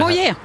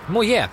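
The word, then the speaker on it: Moyet: Is this love med Alison Hjälp mig, jag kan aldrig uttala det. – Moje. – Moje, så är det.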